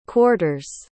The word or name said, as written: quarters